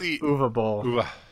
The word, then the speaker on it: Uwe Boll: You, whatever. I always say it wrong. – You know what? Uwe Boll. – Uwe.